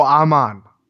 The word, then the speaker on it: Ahman: And Almond Green, is that right? – Almond Green? – Almond. I'm probably pronouncing it wrong. A-H-M-A-N? – A-H-M-A... Oh, Amon. Amon Green.